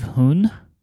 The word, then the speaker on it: hon: It's called, uh, I believe, Hoon, H O N, but it says pronounced of a long O, which means book okay.